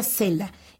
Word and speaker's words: cella: Viene de cella y se pronuncia cela y quiere decir celda.